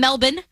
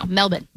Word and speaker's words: Melbourne: Apparently, they don't pronounce the R. – Okay, so no R's. – No, Melbourne.